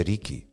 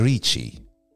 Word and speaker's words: Richie: Tra l'altro, cioè, scoop, oh, eh, eh, no, non si dice Ricky, si dice Richie.